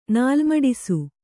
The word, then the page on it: ನಾಲ್ಮಡಿಸು: kannada